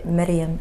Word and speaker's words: Meryem: Et pour les intimes, c'est Myriam Sassi. parce que c'est pas tout le monde qui le prononce ici, donc je dis.